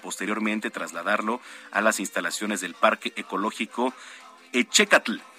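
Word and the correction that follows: Ehécatl: Parque Ecológico Ecatl. A ver, si es Ecatl, perdóneme, así se pronuncia, Ecatl.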